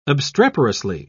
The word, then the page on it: obstreperously: British English